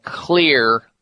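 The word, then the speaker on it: Xlear: And so you'd want to get a nasal spray called Clear Nasal Spray. And that's spelled X-L-E-A-R, X for X-ray.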